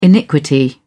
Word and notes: iniquity: British English

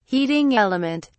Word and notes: heating element: British English

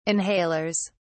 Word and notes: inhalers: British English

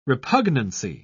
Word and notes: repugnancy: British English

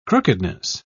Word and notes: crookedness: British English

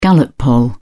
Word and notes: gallup poll: British English

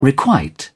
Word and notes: requite: British English

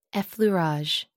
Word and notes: effleurage: American English

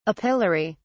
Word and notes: apilary: British English

